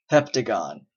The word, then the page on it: heptagon: American English